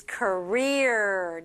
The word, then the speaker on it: career: But the pronunciation for native speakers is career.